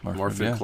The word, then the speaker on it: Morfydd: Morfydd Clark, right? – Yeah.